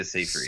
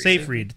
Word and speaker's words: Seyfried: So for those of you who have seen In Time with Justin Timberlake and Amanda, is it Seyfried? Am I pronouncing her name right? – Amanda Seyfried. – Seyfried, thank you.